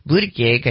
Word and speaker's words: Buttigieg: The increase in delays and cancellations come just days after Transportation Secretary Pete Buttigieg, I think that's how you say it, urged airlines.